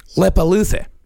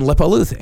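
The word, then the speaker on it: Leppalúði: These I know I'm actually pronouncing right. – Okay. – There is Grilla and Lepaluthe. – Huh. – Yeah. Grilla and Lepaluthe.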